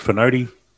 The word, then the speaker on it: Fonoti: I've heard Fonoti. Yeah, it's Fonoti.